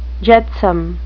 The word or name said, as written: jetsam